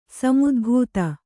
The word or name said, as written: ಸಮುದ್ಭೂತ